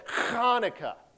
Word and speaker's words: Hanukkah: We don't spell it with the C. You know why? Because we have no sound in the English language. It requires a lot of phlegm, all right? So we drop the C. So it's actually said Hanukkah is how you say it.